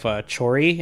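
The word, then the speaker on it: Choerry: The next song I want to put on is from July's debut of Chori, and I'm not saying that incorrectly. It's literally the word cherry with a O before the E, with her single Cherry Motion Love.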